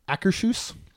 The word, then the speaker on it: Akershus: Akershus.